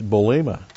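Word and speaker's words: bulema (βούλημα): It's bulema. Bulema. B-O-U-L. Long E-M-A. Bulema.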